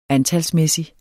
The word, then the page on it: antalsmæssig: [ ˈantalsˌmεˀsi ]; [ -ˌmεsi ]